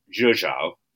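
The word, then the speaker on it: Rzeszów: Yeah, so I flew Ryanair to a place called I can never pronounce this right, Zhuzhou. That's not how it's spelled, but that apparently is how it's pronounced, which is the closest airport to the Ukrainian border because you can't fly to Ukraine because it's just the military.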